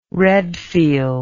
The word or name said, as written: Redfield